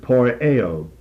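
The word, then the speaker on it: poieo (ποιέω): This is the Greek word poieo, P-O-I-E-O, the last O being omega, that's the long O, poieo.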